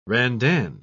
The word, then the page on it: randan: British English